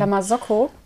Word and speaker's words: La Marzocco: Von La Marzocco. Habe ich es richtig ausgesprochen? – La Marzocco? Ja. Die habe ich tatsächlich bei einem Barista-Wettbewerb gewonnen.